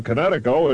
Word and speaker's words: Kinetico: Everybody knows that Kinetico is spelled K-I-N-E-T-I-C-O. – Well, I thought the instructor said Connecticut. Oh, that means coast. – Son, you should have remembered kinetic as in kinetic energy with an O on the end. Kinetico. – Kinetico. Spell it K-I-N-E-T-I-C-O.